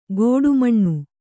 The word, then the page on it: ಗೋಡುಮಣ್ಣು: gōḍu maṇṇu